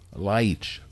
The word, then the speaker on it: Leitch: Leitch. This episode is all about how to pronounce weird last names. L-E-I-T-C-H.